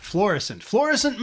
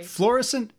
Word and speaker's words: Florissant: Florissant, Missouri. Or is it Missouri? – Missouri. – Florissant, it's not floss. What are you, flossing? You're doing that dance, the floss. It's not floss, Missouri, it's Florissant.